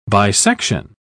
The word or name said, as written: bisection